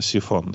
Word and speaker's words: Psiphon: Из самых любимых и самых популярных, наверное, это программа VPN, такая бесплатная программа, которая называется Siphon. Она пишется Psiphon. Читается, естественно, Siphon, да?